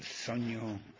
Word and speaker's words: sogno: Leontine Price, La Rondine, Chi, Il Bel Sonno. Oops. Chi, Il Bel Sogno di Doretta. Am I pronouncing that right? Probably. Was close to it. – Yeah, that was good.